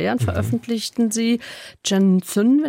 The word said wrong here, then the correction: Shenzhen: wenn ich das richtig ausspreche, Zukunft in China, über diese Metropole Shenzhen. Oder wie sprechen Sie im Süden China? – Shenzhen. – Ah, ganz falsch, okay.